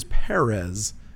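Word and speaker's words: Pérez: He is Perez and actually kind of gets annoyed with people who pronounce his name differently.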